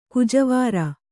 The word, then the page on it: ಗಜವೈರಿ: gaja vairi